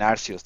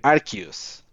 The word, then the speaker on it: Arceus: No dicen Arceus, dicen Arceus. Así.